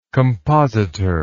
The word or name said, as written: compositorial